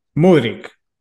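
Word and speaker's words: Mudryk: Mudrik.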